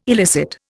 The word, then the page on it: elicit: Indian